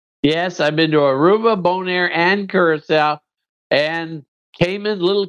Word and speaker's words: Bonaire: I heard him say boner. – No, he said Bonaire.